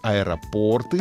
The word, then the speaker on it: аэропорты: Чтобы запомнить правильное ударение в слове «аэропорты», нужно вспомнить э, другое название штанов — «порты». И тогда все встанет на место.